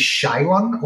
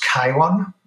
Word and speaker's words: Chiron: So the website, my name and my company is Chiwon, although I know in US it's pronounced Chiwon.